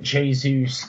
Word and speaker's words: Jesus: And I'm just hoping that Jesus can provide, we've been informed that that's how you pronounce his name, by the way. I'm just hoping that Jesus can provide some sort of, I don't know, just a shot in the ass, man, because this team needs it.